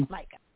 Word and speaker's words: Maika: Maika, is that how you say her name? Maika? – Mm-hmm. – Maika.